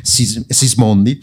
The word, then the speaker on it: Sismondi: Euh, oui, la, la, loi Sismondi, je, je n'avais compris parce, qu'en en, en, tant qu'italienne, je le prononce Sismondi.